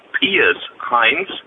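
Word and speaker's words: Pius Heinz: I'm just listening to your podcast, and I wanted to inform you it's pronounced Piers Heinz.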